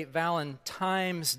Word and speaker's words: Valentine's: Let's look at how the word is spelled just to make sure that we're clear. It's very clearly an N, so you Valentine's people need to get on board.